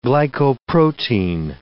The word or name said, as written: glycoprotein